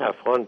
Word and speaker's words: افغان: یعنی از و این در عربی چون کلمه پی و گاف نیست افغان تلفظ شد و در پشتو هم همیشه کلمه گاف به غین تبدیل میشه.